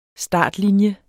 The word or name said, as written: startlinje